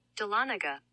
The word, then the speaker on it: Dahlonega: So Delanga, no. – Georgia. Yeah. – Delanaga. – Delanaga, Georgia is the 30 minutes north. So if anybody. – It really does make sense because it's spelled Dahlonega.